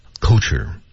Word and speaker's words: culture: 嗯，还有一个单词最容易出错，也是这个音，叫 culture。这个单词我刚才的读音是错的。对啊，正确的读法必须舌位在那个位置，叫 culture。